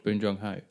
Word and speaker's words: Bong Joon Ho: Bong Joon Ho. – Bong Joon Ho. There you go. – Yeah. – That's how you're supposed to pronounce it.